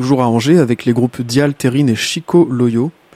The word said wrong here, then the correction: Chica Loyo: J'espère que je prononce bien. – C'est Chica Loyo.